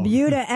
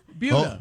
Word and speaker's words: Buda: Buda. – It's spelled B-U-D-A, and I looked it up. It is pronounced like beauty.